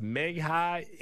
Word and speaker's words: Meghie: and I think her name is pronounced Meghie, M-E-G-H-I-E.